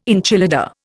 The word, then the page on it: enchilada: Indian